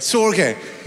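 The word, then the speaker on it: storge: Sorge, but am I pronouncing it correctly? Perfect. Sorge.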